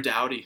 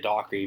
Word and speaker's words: Doughty: Drew, Drew Doughty. Drew Doughty. I always know, but for some reason I always want to say Dockery, even if it's nothing like that. Drew Doughty, 26 minutes and 23 seconds average ice time, which is a lot.